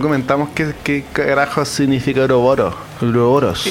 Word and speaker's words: Uroboros: Y se pronuncia en español que Uroboros. – Uroboros.